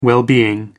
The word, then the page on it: well-being: American English